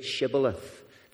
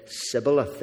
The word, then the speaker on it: shibboleth: The problem was that the Ephraimites, because of their accent, couldn't say shibboleth. They said sibboleth. They couldn't pronounce the H.